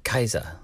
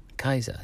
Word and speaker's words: Caesar: You know, and Julius Caesar would not have been Julius Caesar, he would have been Julius Kaiser. Kaiser, you know, just like the Germans.